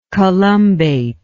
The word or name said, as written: columbate